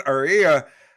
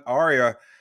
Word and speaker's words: Ariya: Today's episode is brought to you by the new Nissan Ariya, Nissan's most electric player of the week. They're brought to you by the all new, all electric 2023 Nissan Ariya. Sorry, I mispronounced it the first time.